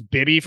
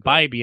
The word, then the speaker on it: Bibee: Now, I thought his name was Bibby for the longest time. – Bibby? Until you just said that. – Yeah. So before the show, I had to look up the pronunciation. It was actually last night. I think I was flipping through games and they talked about Tanner and they said Bibee.